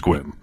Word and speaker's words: Sequim: Especially because it's spelled S-E-Q-U-I-M, Sequim. Yeah. It's Squim.